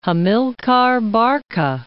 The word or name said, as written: Hamilcar Barca